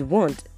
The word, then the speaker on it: want: Now these words are words in which letter A is a pair right after W or after QU and the sound is O. Remember, these are commonly mispronounced words. So we have words like want. I still hear people say want. Like the word is want, want.